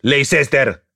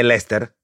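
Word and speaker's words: Leicester: Στο πώ το καλό να προφέρει τα αγγλικά τοπονύμια, που δεν θε να είσαι και τελείω Ελληνάρα και να είσαι, ξέρω εγώ, Λεϊσέστερ ενώ είναι Λέστερ ή Greenwich, ενώ είναι Greenwich αλλά και πάλι θε να το πει και λίγο.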